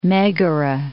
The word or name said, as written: Megara